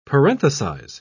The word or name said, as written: parenthesize